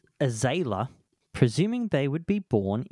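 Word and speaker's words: Azayla: Azalea, sorry.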